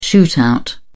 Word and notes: shootout: British English

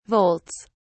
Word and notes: volts: British English

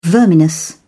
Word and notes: verminous: British English

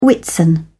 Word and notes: whitsun: British English

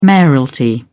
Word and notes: mayoralty: British English

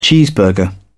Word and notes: cheeseburger: British English

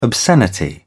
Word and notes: obscenity: British English

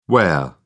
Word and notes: ware: British English

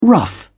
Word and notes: rough: British English

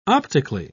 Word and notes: optically: British English

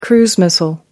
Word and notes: cruise missile: American English

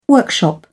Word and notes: workshop: British English

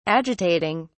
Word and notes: agitating: British English

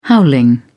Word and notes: howling: British English

